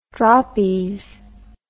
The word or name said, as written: droppies